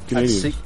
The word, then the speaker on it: Canadiens: How do you say that anyway? Canadiens? – Yep, Canadiens.